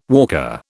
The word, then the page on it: walker: British English